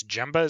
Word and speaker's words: Gemba: One studio we forgot to mention when we were talking about studios I want to bring up now is, I think it's Gemba is how it's pronounced, G-E-M-B-A.